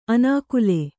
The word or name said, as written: ಅನಾಕುಲೆ